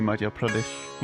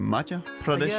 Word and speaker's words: Madhya Pradesh: Fantastiske øh, Madhya Pradesh. – Madhya Pradesh. Så er det sådan, man udtaler det? – Is it uh, Madhya Pradesh? – Yeah, Madhya Pradesh is the central state of India. Uh, so uh, you are very right.